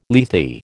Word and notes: Lethe: American English

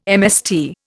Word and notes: MST: Indian

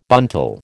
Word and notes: buntal: American English

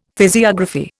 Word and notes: physiography: Indian

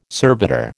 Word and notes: servitor: American English

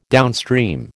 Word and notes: downstream: American English

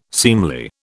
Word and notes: seemly: British English

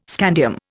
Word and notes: scandium: Indian

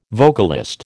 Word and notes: vocalist: American English

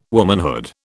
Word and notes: womanhood: British English

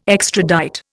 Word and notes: extradite: Indian

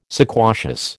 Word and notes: sequacious: American English

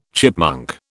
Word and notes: chipmunk: British English

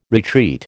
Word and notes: retreat: American English

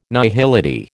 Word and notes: nihility: American English

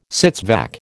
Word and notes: sits vac: American English

